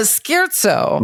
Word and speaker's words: scherzo: A scherzo. Mm-hmm. Am I pronouncing that correctly? – Perfectly correct.